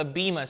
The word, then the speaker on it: Bema: It's called the Bema seat, if you're trying to spell that, B-E-M-A, the Bema seat.